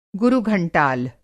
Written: गुरुघंटाल

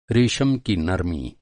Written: नरमी

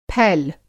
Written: फैल

